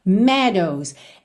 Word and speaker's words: meadows: A, A, fragrant, fragrant, meadows. Essa palavra se escreve M-E-A-D-O-W-S, mas você não fala meadows, só meadows, meadows, ok?